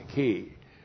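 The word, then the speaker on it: key (כי): K, Long I, key.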